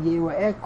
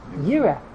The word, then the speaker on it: Yeroeh: So the Gemara makes a drasha, even though we pronounce it Yeroeh, it can also be pronounced Yireh.